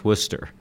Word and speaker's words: Worcester: And he's like, oh, that's where I'm from, but it's pronounced Worcester.